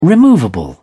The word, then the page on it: removable: British English